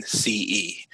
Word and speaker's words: Nigrescence: So can you tell me, what is Nigrescence Theory? What is that all about? – Love it. So let's start with the literal definition of the word nigrescence. – Oh, I'm saying it wrong. Nigrescence. Pardon me.